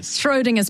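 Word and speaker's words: Schrödinger's: How do you say that? – Schrodinger's plates. I don't know why I feel like there's an L in there. There's not.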